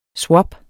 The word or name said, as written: Swap